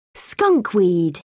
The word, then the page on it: skunkweed: British English